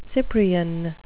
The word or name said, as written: cyprian